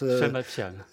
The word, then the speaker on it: Sima Qian: La première tentative sérieuse de relater la vie du maître, vous le rappelez, donc revient à Sima Qian. Vous me corrigez si je prononce... – Sima Qian.